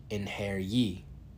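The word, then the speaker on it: Einherji: Yeah, singular. Inheriar. He said it like in-hair-ye.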